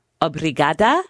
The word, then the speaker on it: obrigada: Now, tell me if I'm saying this right. Obrigada? – Yeah. Yeah, and, you got it right.